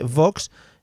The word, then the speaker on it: Vox: Oye, que bien has pronunciado Vox, ¿no? Sí, porque estoy intentando traer otra vez las, las dobles y la buena pronunciación de la W al castellano. Se está perdiendo.